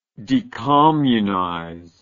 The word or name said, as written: decommunization